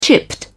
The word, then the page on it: chipped: British English